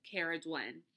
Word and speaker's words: Cerridwen: It is spelled Cerridwen, Cair Dhuinn.